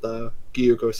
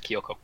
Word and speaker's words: Gyuko: And you continue following this thing as it like leaps from, you know, chunk of rubble to chunk of rubble with Gyuko screaming. You come across a... Yeah, sure. – Okay. So I had it completely wrong. I thought it was Kyoko.